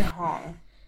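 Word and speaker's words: Hoang: Helen Hong.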